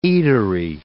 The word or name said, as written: eatery